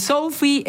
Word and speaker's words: SoFi: El SoFi Stadium de Los Ángeles, si es que lo dije bien, sí, lo dijiste perfecto.